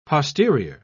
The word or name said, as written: posterior